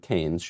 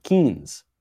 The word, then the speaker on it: Keynes: And even more so, she's not even pronouncing the one guy's name correctly, John Maynard Keynes. She's calling him Keynes, like the steakhouse in New York.